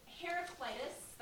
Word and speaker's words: Heraclitus: So Heraclitus, I'm pronouncing that correctly, right, Petter? Okay.